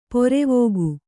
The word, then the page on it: ಪೊರೆವೋಗು: porevōgu